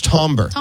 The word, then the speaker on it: timbre: It's Tombra. – But it's spelled timber.